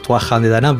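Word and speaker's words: Tuatha Dé Danann: Los Tuatha de Danann son los, bueno, los irlandeses los pronuncian diferente, los Tuathan de Danann, pero sí, para los Tuatha de Danann, pues ellos realmente eran el penúltimo pueblo mitológico que habitó aquí la isla de Irlanda.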